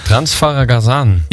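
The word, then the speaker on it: Transfăgărășan: Transfegarazan.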